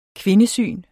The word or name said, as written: kvindesyn